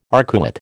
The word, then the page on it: arcuate: American English